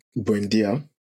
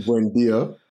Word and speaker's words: Buendia: Emily Bundeya. And Mings. – I don't think that Bundeya. You almost made me use your US pronunciation. I don't think that Buendia was really as effective while he was not injured under Emery.